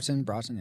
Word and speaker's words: Broughton: And the music was composed by Bruce Broughton. I think that's how you pronounce his name. – Sure. Broughton, yeah.